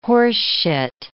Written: horseshit

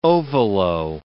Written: ovolo

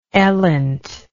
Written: elint

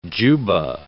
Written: juba

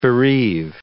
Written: bereave

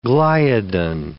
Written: gliadin

